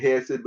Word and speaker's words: chesed: We pronounce it hesed, but it will be pronounced like with a CH, like hesed.